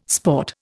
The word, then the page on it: sport: Indian